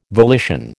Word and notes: volition: American English

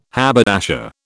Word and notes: haberdasher: British English

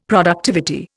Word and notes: productivity: Indian

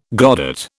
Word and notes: godet: British English

